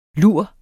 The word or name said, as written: lur